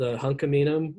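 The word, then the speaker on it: hən̓q̓əmin̓əm̓: And that's something that, so our dialect, Halkaminum. – Oh, I keep saying it wrong. I'm sorry. – So actually, like in Musqueam, it's called Hunkaminum. And there's another dialect, I think that's Halkamalam, that's further upriver.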